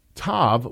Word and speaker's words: tav: Thus, tav would have been pronounced as tau rather than as tav.